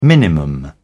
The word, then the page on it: minimum: British English